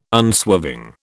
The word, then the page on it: unswerving: British English